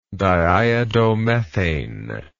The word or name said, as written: diiodomethane